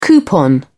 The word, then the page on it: coupon: British English